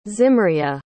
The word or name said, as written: zimria